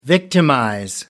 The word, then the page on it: victimize: American English